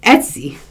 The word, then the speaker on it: Etsy: Like, who don't want to run the website, and they don't want to run, you know, like, I want to call it Estes, but it's not Esty. What is it? – Etsy.